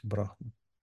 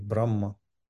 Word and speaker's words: Брахма: Неважно, тоже ведийское божество Праджапати, Брахма или Брахма. Разным произносят. Такое есть правило. Некоторые говорят, что это из хинди такое произношение Брахма. Но в некоторых ведийских шикшах встречается объяснение, что так может произноситься в некоторых случаях.